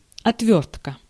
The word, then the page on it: action: /ˈæk.ʃən/; [ˈæk.ʃən]